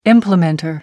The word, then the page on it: implementer: American English